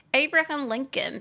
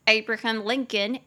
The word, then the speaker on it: Abraham Lincoln: 我就帮大家补充一句，顺便学一下那个亚伯拉罕林肯和约翰甘乃迪他们的全名怎么发音，你就可以说 Abraham Lincoln and John F. Kennedy were both assassinated. Abraham Lincoln and John F.